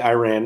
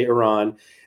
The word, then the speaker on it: Iran: Apparently, you're not supposed to say Iran, it's supposed to say Iran.